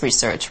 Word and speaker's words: research: But when it's a verb, it's pronounced as research, right? – Yes, you research.